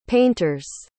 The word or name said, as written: painters